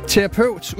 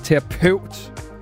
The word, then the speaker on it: terapeut: Terapeut udtales terapeut, og ikke terapeut.